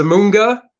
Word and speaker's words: Zamunda: How do you pronounce Zamunda. it? Zamunda.